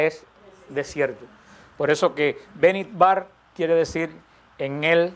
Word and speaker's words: Bemidbar: Este libro, el libro de Números, se pronuncia en hebreo Bemidbar. Bemidbar. Se pronuncia en hebreo.